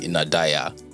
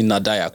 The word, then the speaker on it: Nadayar: And today, although this had been a long time in the works, really, we have in the studio here with me, Nadayar Enu. – My last name? – Yeah. – Enegesi. – Did I even pronounce the Nadayar correctly? – Yeah, that's correct.